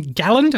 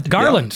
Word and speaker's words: Garland: Herman has a question in Galland. Did I say that right? – Garland.